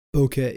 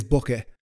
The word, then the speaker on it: Bokeh: Now, in regards to disclaimer, not so much a disclaimer, it's more just usually if you look at the phonetic spelling of how to pronounce Bokeh is, you know, B O H K A Y. That's how they say it's correctly pronounced.